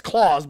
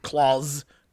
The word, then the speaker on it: Claus: Because, it's Claus, but it's like Claus, because it's like, you know, the contract. There's a clause in it.